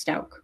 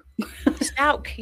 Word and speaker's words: Stauch: Also, we've been saying her last name wrong this whole time. It's Stauch. – Stauch, yeah.